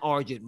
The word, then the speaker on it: Argent: I'm like Argent, rhymes with Sergeant.